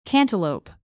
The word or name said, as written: cantaloupe